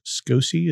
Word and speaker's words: SCOCE: Well, we were recently at a conference that we're calling SCOCE. Is that how we're going to pronounce it? – Yes.